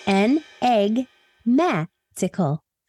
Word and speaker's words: enigmatical: So without further ado, today's word of the day is enigmatical, spelled E-N-I-G-M-A-T-I-C-A-L, and pronounced N-Egg-Ma-tical.